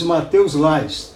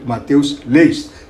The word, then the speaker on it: Matheus Leist: Ou Who the hell is Matheus Last? Ou, desculpe, Matheus Leist. É Last porque é um nome alemão, mas aqui no Brasil deve-se manter a pronúncia abrasileirada. Então é Matheus Leist, como ele se chama.